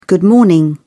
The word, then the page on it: good morning: British English